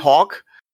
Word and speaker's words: Haack: aber Matt Hawk ist inkonstant. Wer sich übrigens wundert, wird zwar H-A-A-C-K geschrieben, wird ausgesprochen.